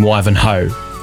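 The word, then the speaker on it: Wivenhoe: If you're a true coaster, do you say Wivenho or is it actually Wivenho? Emma's written in saying you 100% do pronounce the H in Wivenho, according to Emma.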